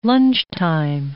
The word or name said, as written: lunchtime